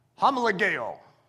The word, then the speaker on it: homologeo: Homologeo, homologeo, just in case you're having trouble with the pronunciation.